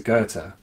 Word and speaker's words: Goethe: And so you mentioned Steiner, and Steiner, I think, comes from the long school of like German idealism, going back to Goethe, if that's how you pronounce it. – Yeah, that's right.